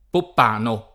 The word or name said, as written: Poppano